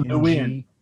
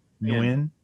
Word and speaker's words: Nguyen: Nguyen, maybe? – No, Nguyen. – Nguyen. Thank you. – It's Vietnamese.